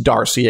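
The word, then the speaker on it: D'Arcy: Oh, by the way, Lamont says it's just Darcy. Ignore the apostrophe.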